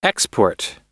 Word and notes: export: American English

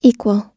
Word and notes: equal: American English

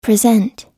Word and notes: present: American English

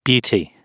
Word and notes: beauty: British English

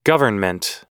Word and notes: government: American English

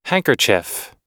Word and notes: handkerchief: American English

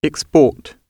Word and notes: export: British English

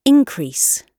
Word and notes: increase: British English

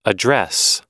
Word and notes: address: American English

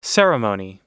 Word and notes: ceremony: American English

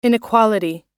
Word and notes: inequality: American English

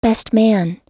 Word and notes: best man: American English

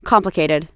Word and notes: complicated: American English

